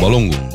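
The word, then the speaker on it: Balogun: And there's a proper way to say his name. – Balungun. – Balungun, out of this upcoming match against Belgium.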